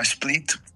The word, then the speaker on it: Split: Tuvimos la experiencia, por ejemplo, en una ciudad que se llama Split, así como dividir en inglés.